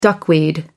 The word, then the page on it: duckweed: American English